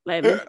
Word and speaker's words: Lebanon: Yeah, they spell it Lebanon, but in Kentucky, would they say Lebanon? Lebanon.